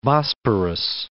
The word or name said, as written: Bosporus